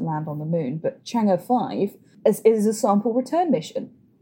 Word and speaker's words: Chang'e: This was the Chinese space agency's third trip to the moon, with Chang'e 5, it was called. Actually, I believe it's pronounced Chang'e 5, my apologies.